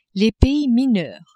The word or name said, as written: pays mineurs